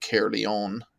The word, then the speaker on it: Caerleon: But then in other cases, that C-A-E-R is actually pronounced Care. So like for the town of Caerleon, that's how it's pronounced.